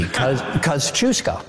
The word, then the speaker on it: Kosciuszko: The first syllable is cuz, like the word cousin. The second one is choose, like choose or lose. Cuz, choose, co. – You made this so easy. Kosciuszko.